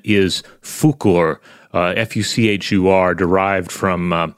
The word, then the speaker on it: Fuchur: And apparently it was changed in the English translation because the name Fuchur sounds too much like an English language swear word.